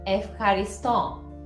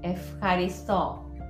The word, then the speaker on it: Ευχαριστώ: So, the thank you in Greek is Ευχαριστώ. Ευχαριστώ. F and a ha is like a ha. Like a Ευχαριστώ. – Ευχαριστώ.